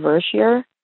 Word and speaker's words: Vershire: I grew up in Vershire, V-E-R-S-H-I-R-E, and that's how we've always pronounced it, Vershire.